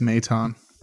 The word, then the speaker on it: Maton: and Phil Maton. I'm pronouncing that right. – I think it's Maton. – Matt Maton.